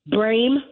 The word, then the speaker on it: Brame: Okay, it's Win Red, WINRED, Brame, my last name, BRAME, for Congress.